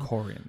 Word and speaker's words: Corrigan: Corrigan, that's how you say it.